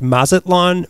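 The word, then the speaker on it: Mazatlán: But since he was minister to Mexico, he started formulating a plan that would have linked Mazatlan, Mexico. Is that how you pronounce it for me? That's right. You got it, boy. – Yeah, you got it. – So he wanted to connect Mazatlan, Mexico to New York City by train.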